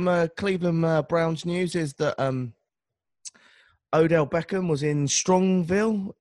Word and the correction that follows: Strongsville: if I said that right. Is it Strongville? Doing his camp? – Yeah, Strongsville.